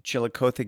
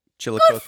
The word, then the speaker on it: Chillicothe: Chillicothe Gazette? – Yes! – Chillicothe.